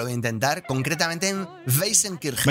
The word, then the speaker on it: Weißenkirchen: No tengo ni idea, es Weißenkirchen. – Es justo así. – Pero yo digo Weißenkirchen.